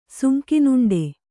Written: ಸುಂಕಿನುಂಡೆ

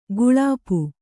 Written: ಗಳಪ

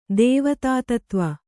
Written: ದೇವತಾತತ್ವ

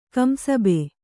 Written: ಕಂಸಬೆ

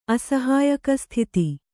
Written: ಅಸಹಾಯಕಸ್ಥಿತಿ

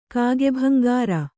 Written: ಕಾಗೆಭಂಗಾರ

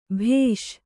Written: ಭೇಷ್

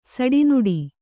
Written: ಸಿಡಿನುಡಿ

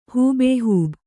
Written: ಹೂಬೇಹೂಬ

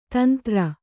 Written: ತುಂತುರು

